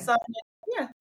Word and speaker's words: Sonja: Sonja. Yeah. – Sonja, Sonja. Okay. Sometimes the O's. Sonja. Okay. – Yeah, it's a long O's.